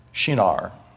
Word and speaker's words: Shinar: And then he said to me to build a temple for her in the land of Shinar. It's like when you get a shiner on your eye. I had to look up the pronunciation.